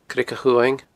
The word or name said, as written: Creag a' Chumhaing